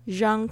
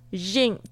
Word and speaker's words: Jean: OK， Jean Carles，为什么那个本来是 J-E-A-N Jean Jean 为什么会念成 Jean、其实它就是法国的名字、它其实就是等于男生的名字的 John。